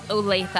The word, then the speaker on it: Olathe: They're like, it is pronounced Olathe.